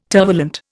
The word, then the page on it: tervalent: Indian